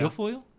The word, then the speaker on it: Guilfoyle: G- Guilfoyle. Guilfoyle. – Yeah. Guilfoyle.